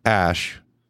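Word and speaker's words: Æ: And then he said, Musk told Joe Rogan that it's X, as in the letter X, and then the A-E is pronounced Ash, and then the A-12 is my contribution, so it's...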